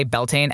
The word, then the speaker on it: Beltane: People do say Beltane, and that's the other thing with these pronunciations too. So depending on what part of Ireland or Scotland you're in, they're going to pronounce these differently anyway.